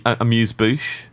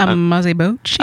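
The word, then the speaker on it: amuse-bouche: You've just learned how to say it properly. – Yeah, I've just learned how to say amuse. Amuse bouche. – Yeah, yeah. – Was that? – I've been saying it very wrong, and then Ed corrected me on it.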